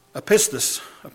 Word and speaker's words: apistos: There's a Greek word, it's called, if I pronounce it right, apistos, maybe, A-P-I-S-T-O-S.